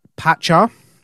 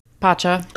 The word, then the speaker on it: Pacha: Next, we have Roots of Pacha. Hey, I said it right that time. – Pacha.